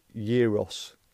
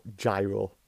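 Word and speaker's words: gyros: It's pronounced Euros, not gyro, as you were saying. It's Euros.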